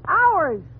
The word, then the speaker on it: hours: H-O-R? Oh, that's easy. That's horse. – Fine. Now, how do you pronounce H-O-U-R-S? – H-O-U-R-S? Howers? – You pronounce it ours.